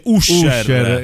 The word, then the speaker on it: Usher: Si scrive Usher. – Esatto, come Pusher. – Come uscere.